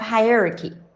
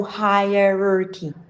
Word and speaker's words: hierarchy: Many people find it hard to guess how to pronounce hierarchy. Has an unfamiliar spelling and different vowel sounds. So they often think that there are only three syllables instead of four. So hierarchy, hierarchy. Yay!